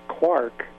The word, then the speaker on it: quark: Got my physicist wrong then, but the original intention was for it to be pronounced quark, because the line is three quarks for Muster Mark. So quark rhymes with mark.